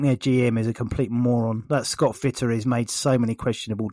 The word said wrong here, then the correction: Scott Fitterer: Scott Fitterer. Say it properly, please.